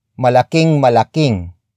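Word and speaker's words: malaking-malaking: Word for word, it's malaking malaking suliranin. Malaking malaking suliranin. Malaking malaking suliranin. The first word is malaking malaking. Malaking malaking. Malaking malaking. Malaking malaking is spelled as M-A-L-A-K-I-N-G hyphen M-A-L-A-K-I-N-G. It means very big.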